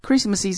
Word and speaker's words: Christmas: 注意，圣诞的话就是 Christmas，Christmas。中间的 t 是不发音的，但是呢，前面的词把 m a s 去掉，c h r i s t 读作 Christ，Christ，Christ 就是耶稣基督的意思啊，所以呢，这两个词发音是有变化的。那我们就继续往后看了。 Christmas is also sometimes known as Xmas.